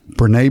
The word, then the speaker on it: Brené: Brené Brown.